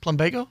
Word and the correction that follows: Plumbago: Plumbago? – Plumbago. – Plumbago? – Plumbago. – Plumbago? – Right.